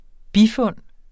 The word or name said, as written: bifund